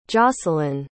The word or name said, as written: jocelynn